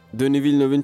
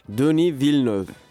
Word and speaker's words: Denis Villeneuve: Denis Villeneuve'ün çekecek olması. – Nasıl okunuyor bir daha söylesene. Dune. Hayır. Denis Villeneuve. Dönü Villeneuve. Repeat after me. Denis Villeneuve. Dönü Villeneuve. Aynen. Güzel